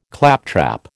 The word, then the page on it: claptrap: American English